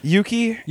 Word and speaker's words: Yuki: Yuki? – Yuki, yeah. – Yuki. – So it's I keep saying it wrong. I just I see the UK, and I'm just like, Uki.